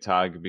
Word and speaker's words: Tog: I'm pronouncing it Tog because it's German. – Guten Tag. – Right, like that.